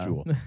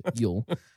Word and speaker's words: Jul: Everybody kind of agrees that I saw being traced back to the Vikings, and they had Yule, which is spelled J-U-L, evidently. That's a thing. – Not Jewel. – Right, not Jewel. It's Yule. And they brewed some kind of a beer.